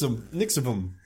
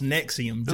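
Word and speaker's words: NXIVM: Nixivum. That's what I would say. Nixivum. – I mean, I saw in multiple articles, it is pronounced Nexium, just right. like the antacid.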